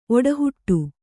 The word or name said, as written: ಓಟಹೊಡೆ